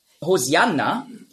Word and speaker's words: Hosianna: Hosianna ist einfach die griechische Aussprache von Hoshi'anna. Hosianna, das ist die griechische Aussprache. Weil die Griechen, die haben im Alphabet kein Sch-Laut. Nurz. Die Hebräer schon. Auf Hebräisch sagt man Hoshi'anna. Aber Griechen können das nicht.